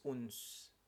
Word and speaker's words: Odense: It's actually pronounced as Uns. The Danish pronounce it Uns, but it's spelled as Odense.